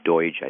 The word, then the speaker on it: Doidge: I just finished a book your guests may know of called The Brain That Changes Itself by Norman Doidge, I think his name is pronounced, Dr. Doidge.